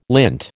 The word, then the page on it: lint: American English